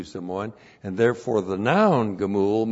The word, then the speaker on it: Gemul: And then the noun gemul, looks like that. That's G, Shiva, M, long U, L, Gemul.